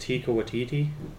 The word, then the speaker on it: Taika Waititi: Tika, I think it's pronounced Taika Waititi.